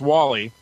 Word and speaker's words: WALL-E: What do you know about this movie, Wall-E? Is that how you pronounce it? – Yeah, it's Wall-E.